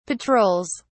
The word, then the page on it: patrols: British English